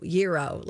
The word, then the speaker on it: gyro: Isn't it Yairo? Yairo, Yiro. Yiro, like hero. – Well, it's spelled gyro. G-Y-R-O.